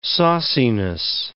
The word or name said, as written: sauciness